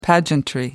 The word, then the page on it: pageantry: American English